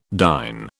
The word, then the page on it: dyne: British English